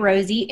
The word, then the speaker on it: Rosy: So we're available on Apple and Android and you just go to the App Store and just type Rosie. It's spelled R-O-S-Y.